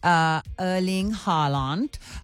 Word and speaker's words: Erling Haaland: And thank you so much, Renee, for just reaffirming that I am saying his name correctly, because I said I don't speak Norwegian. Erling Haaland.